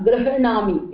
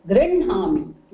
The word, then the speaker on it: गृह्णामि: So here it is not grahanami, it is grinhami. Na comes first.